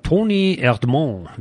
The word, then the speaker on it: Toni Erdmann: In diesem Jahr spricht man kaum über einen Film mehr als über Toni Erdmann oder Toni Erdmann, wie der Franzose und wie die Franzosen sagen.